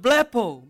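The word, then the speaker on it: blepo (βλέπω): And that word is pronounced blepo. Blepo, it means to see something physical with spiritual results.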